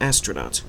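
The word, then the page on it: astronaut: Canadian English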